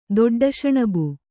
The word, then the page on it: ದೊಡ್ಡಶಣಬು: Kannada